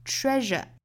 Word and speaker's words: treasure: 这句话里的 pleasure，后面一句里的 treasure，这两个词里的这个 s 字母啊，对应的发音呢应该是浊辅音的 r 的发音，大家不要发成了 treasure pleasure 之类的。